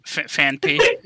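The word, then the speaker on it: Phanpy: Hang on, Fanfy? Fanafy? I don't know how to say his name. – Fanpy. Fanpy.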